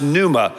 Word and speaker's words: pneuma: This word breath in the original language is pronounced pneuma.